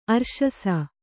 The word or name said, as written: ಅರ್ಶಸ